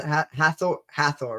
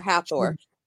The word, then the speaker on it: Hathor: I don't know how many people are familiar with Hathor, but how do you pronounce it? Hathor, right? – Yeah, Hathor.